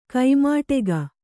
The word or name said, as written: ಕೈಮಡಗು